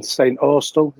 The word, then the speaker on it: St. Austell: I've only ever been twice to the same place we went to stay in, St. Austell, is that how you say it? – Oh, yes, yeah, cool.